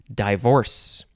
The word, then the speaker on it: divorce: Or some people say divorce. You emphasize the second syllable, so it's divorce. I just say divorce.